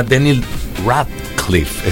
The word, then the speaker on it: Daniel Radcliffe: Y llega Potter, que se llama Daniel Radcliffe.